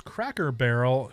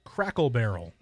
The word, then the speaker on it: Cracker Barrel: Texter says, one that I have a problem with is Cracker Barrel. She always says Crackle Barrel.